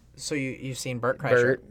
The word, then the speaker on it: Kreischer: Kreischer. It's not even hard. It's Kreischer. – It doesn't look like it's Kreischer. – No, don't look at it. Just say it. – Because Bert Kreischer.